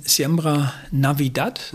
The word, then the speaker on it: Siembra Navidad: Das ist aus eurer CD Siembra Navidad, ist das richtig ausgesprochen? – Genau, ja.